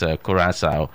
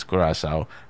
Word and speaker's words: Curaçao: So the right pronunciation is Curaçao and not Curaçao like Rima used to call it.